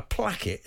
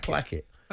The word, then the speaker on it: placket: Is it a plaque or is it a placket? – I think placket. – Placket. Okay. – As in, placket. – Is it right to rhyme with Keith Hackett? – Keith Hackett. – That's right. Yeah.